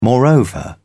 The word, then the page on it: moreover: British English